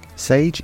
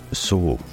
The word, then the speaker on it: sauge: Sage in French is pronounced sauge, or sage, depending on where you are in France. Sauge, though, is spelled S-A-U-G-E.